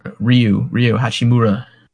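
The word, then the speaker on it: Rui Hachimura: How do you say his damn name? – Yeah, Ryu Hachimura.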